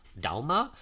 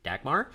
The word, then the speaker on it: Dagmar: So in Danish, that would actually be pronounced Dalma, but everything that we found with English-speaking people, they pronounce it Dagmar.